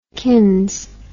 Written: kins

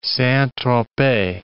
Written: Saint-Tropez

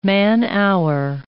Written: man-hour

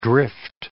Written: drift

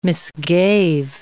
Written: misgave

